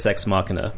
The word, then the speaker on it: Ex Machina: Is that how you say it? – Ex Machina. Yeah, it's like Deus Ex Machina, but without the Deus.